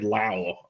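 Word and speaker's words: Lowe: It means more playing time for Brandon Lau. I can't believe that's how they pronounce that name.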